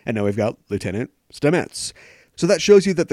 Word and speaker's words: Stamets: is based on real-life mycologist Paul Stamets. And that's how you pronounce his name. Now I know how to pronounce his name.